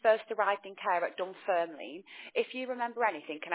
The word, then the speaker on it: Dunfermline: So first memories of care was being sent to an assessment centre, and yeah, that was called Dunfermline, if I can pronounce that right.